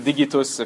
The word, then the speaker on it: digitus: and there was also a Roman measurement called a digitus or digitus, if you want to be proper with your Latin pronunciation or classical, which is means finger, of course, digit, and that was approximately 1.85 centimeters.